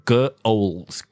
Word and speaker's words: ghouls: 你看这个 G-H-O-U-L, 其实它那个 H 是 silent H. 所以你就想说 ghouls, ghouls.